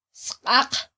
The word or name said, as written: sqaq